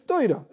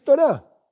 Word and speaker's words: Torá: Cuando uno dice Torah, es la, pronunciación sefaradí, que simplemente se van intercambiando.